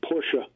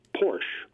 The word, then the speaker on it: Porsche: Well, okay, I have at various times been more or less an avid fan of the Porsche motor car, which is mispronounced by many people as Porsche.